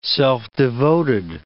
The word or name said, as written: self-devoted